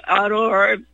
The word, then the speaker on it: Owuor: I wish people would look at a video by a guy from Africa, Kenya, named Dr. David Aurore. It's spelled O-W-U-O-R.